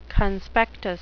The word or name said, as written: conspectus